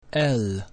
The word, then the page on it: L: Latin